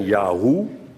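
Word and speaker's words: Jahweh: Want het had moeten zijn Yahoo. Dat stamt uit de 30er jaren, geloof ik, dat iemand dat uitgevonden heeft. Yahoo. Dat vind ik helemaal onbenullig. Yahoo. Dat vind ik net zoiets als hoezo. Ja, hoe?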